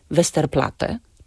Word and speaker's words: Westerplatte: I ponieważ są to nazwy niemieckiego pochodzenia, a w języku niemieckim nie wymawia się podwójnego t, zatem wymawiamy tylko jedną głoskę. Na przykład w nazwie Westerplatte czy ulica Trauguta.